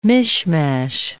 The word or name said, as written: mishmash